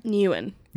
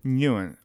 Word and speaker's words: Nguyen: Okay, so how do you say it again? – Nguyen. – Nguyen. Okay. – Yeah, the G's like...